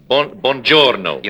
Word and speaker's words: buongiorno: Buongiorno. – You must roll the R. – You roll the R, huh?